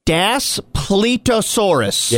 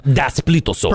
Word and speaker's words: Daspletosaurus: Hold on, I'm going to really pronounce this. Everybody shut up. Shut up for a minute. Okay. Daspletosaurus.